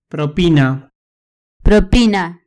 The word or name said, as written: propina